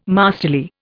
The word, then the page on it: masterly: Indian